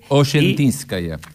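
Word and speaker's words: Osetinskaya: La S, como si fuera SH. – Oyentiskaya. – Y la I es el... viste que siempre cuando estudiamos te dicen, preferentemente esdrújula la palabra en ruso,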